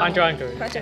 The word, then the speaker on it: Hunter x Hunter: It's called Hunter. You don't pronounce the X. – I know. – Hunter x Hunter.